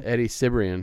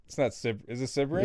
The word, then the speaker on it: Sibrian: Eddie Sebring? – Hell yeah. – Is that how you say his name? – Eddie Sibrian. – Is it Sibrian? – Yeah.